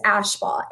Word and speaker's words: Ashbaugh: And then my last name is Ashbaugh, A-S-H-B-A-U-G-H.